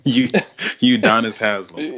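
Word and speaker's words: Udonis Haslem: Udonis Haslam.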